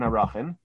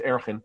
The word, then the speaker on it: Arachin: So I want to share with you a Gemara in Arachin. There are not too many Gemaras in Arachin, often more popularly pronounced in the base Medrash is Erchin. You'll forgive my hyper-correct pronunciation.